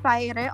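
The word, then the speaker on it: Fyre: Uh, as for Fire, again, for the newcomers in this space, Fire is a F Y R E is apparently pronounced as Fire all this time.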